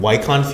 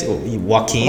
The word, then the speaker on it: Joaquin: I always pronounce his name on Wycon Phoenix. Joaquin.